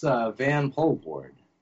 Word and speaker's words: Vanpoelvoorde: It's Vanpoelvoorde.